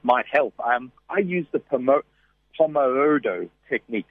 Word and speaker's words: Pomodoro: Pomodoro.